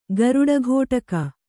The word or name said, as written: ಗರುಡಘೋಟಕ